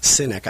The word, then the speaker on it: Sinek: Sinek, I guess is actually how you pronounce it, but he's anything but a cynic.